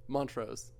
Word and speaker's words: Montrose: What's the proper pronunciation of Montrose? – Montrose. – Yeah. So I'm saying it right, as far as you know? – As far as I know.